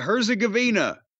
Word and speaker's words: Herzegovina: Herzegovina. – Is that serious, or are you mocking? – I'm not serious. That's how you say it.